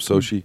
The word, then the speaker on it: Sochi: Putin speaking at a news conference in Sochi, how do you say that? Sochi? In the Black Sea resort of Sochi.